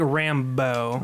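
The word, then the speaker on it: Rambeau: How did I say it? It's like Rambo. – Rambo, okay.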